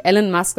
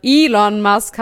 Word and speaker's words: Elon Musk: Elon Musk, ich habe jetzt gerade den Namen das erste Mal richtig ausgesprochen. Ich werde so viel gehatet, mein Gott, weil er nicht Elon Musk, sondern Elon Musk heißt. Jetzt sage ich es immer richtig.